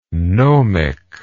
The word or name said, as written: gnomically